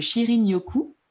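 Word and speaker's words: Shinrin-yoku: On appelle ça, j'espère que je prononce bien, le Shirin Yoku. Euh, donc vraiment, c'est comme ça que ça se prononce oui, il y a un N. C'est ça.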